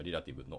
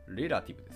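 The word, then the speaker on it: relative: リレーティブ、もしくはリラティブの発音記号を見てますが、レラティブですね。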